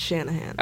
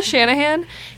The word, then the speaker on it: Shanahan: But there are three A's. – Yeah, but it's Shanahan. – Okay, great. Kyle Shanahan, he does defend the decision to, like, receive kickoff in overtime, right?